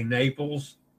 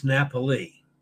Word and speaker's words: Napoli: That's Naples. The Americans say Naples, and it's Napoli.